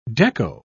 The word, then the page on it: dekko: British English